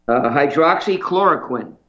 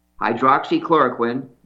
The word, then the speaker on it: Hydroxychloroquine: Hydroxychloroquine. Hydroxychloroquine.